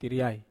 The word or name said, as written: tariai